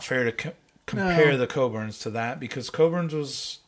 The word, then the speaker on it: Cockburn's: Or it's properly pronounced, apparently. – Coburn's. – It's Cockburn's. – C-O-C-K. K-B-U-R apostrophe. B-U-R-N apostrophe S. – Which is Cockburn's. – Coburn's.